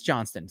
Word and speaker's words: Johnston: Oh, it is Johnston. So I said it right the first time.